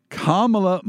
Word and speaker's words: Kamala: Mamala. – So it rhymes with Kamala. – Kamala, Mamala. So if I just think of mom, stepmom, Mamala Harris, Kamala Harris, that's how to pronounce her name.